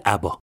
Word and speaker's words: آبا: اسمشون شده ابا ابا به گوش ما یکم غریب است من با همون تلفظی که تو ایران معروفن یعنی آبا صداشون میکنم